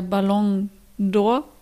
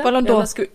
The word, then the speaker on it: Ballon d'Or: Ähm, es war jetzt die, äh, der Ballon d'Or. Josie, habe ich es richtig ausgesprochen? Super. Mhm. – Genau, ein bisschen schneller, ein bisschen flüssiger. – Ballon d'Or.